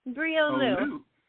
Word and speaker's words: Bree Olu: I got to remember, no hard G, no G at all. Ulu. – Olu. Bree Olu. – Bree Olu.